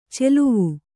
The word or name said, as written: ಚೆಲುವು